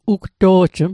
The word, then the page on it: ugto'jm: Mi'gmaq